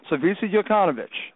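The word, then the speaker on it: Slaviša Jokanović: It is Slavisa Jokanović.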